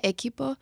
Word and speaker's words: Equipo: You always vamos say Akipo. Isn't it Equipo? – Vamos Akipo. – Vamos Equipo. Speak Spanish.